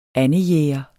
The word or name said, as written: andejæger